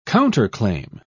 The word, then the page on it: counterclaim: British English